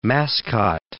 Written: mascot

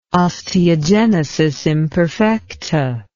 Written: osteogenesis imperfecta

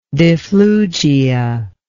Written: difflugia

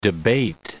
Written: debate

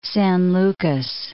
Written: San Lucas, Cape

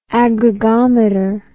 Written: aggregometer

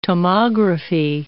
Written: tomography

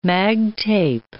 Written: mag tape

